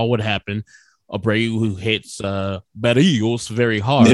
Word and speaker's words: Berríos: No, that's the proper. Yeah, trying to roll your R's. You're trying to feel pay respect. – I feel bad. – It feels a little weird. – Jose Berríos. I'm going to say it very anglicized.